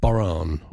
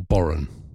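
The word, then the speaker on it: Bodhrán: And, yeah, it's spelt Bodhran, but it's pronounced Boran or Boran.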